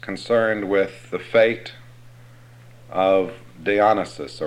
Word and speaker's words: Dionysus: Dionysus.